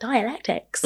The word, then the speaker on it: dialectics: I'm talking about when you put forward ideas and argument, the, that rhetorical sort of exchange, that discourse, that dialectics. Is that how you pronounce it? – That is how you pronounce it.